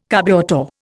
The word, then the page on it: carburettor: Indian